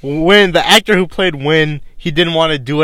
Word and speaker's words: Winn: I think I know the answer to this question, but a Supergirl aside, who is your favorite character whose name I think rhymes with Ben? – Win!